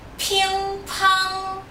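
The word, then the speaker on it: ping-pong (乒乓): Bom, em chinês é ping-pong. Ping-pong. Precisa soprar. – Ping-pong. – Isso. Ping-pong. Tem que manter a boca aberta, som nasal. E os G's são mudos. Ping-pong.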